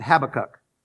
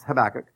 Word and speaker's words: Habakkuk: We pronounce it Habakkuk. But I learned later it's Habakkuk.